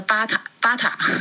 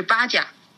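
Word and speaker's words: Baťa: 鞋，我我您您知道 B A 呃 B A T A，以前在国内呢，我们叫它巴塔巴塔，这个正其实真正它的发音是巴甲，就是捷克语。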